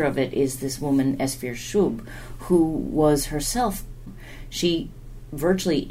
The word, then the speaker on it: Esfir Shub: Esfir Shub is a very good pronunciation of that Soviet name. An American would probably say Esther Shub, but Esfir Shub is how she would have been known.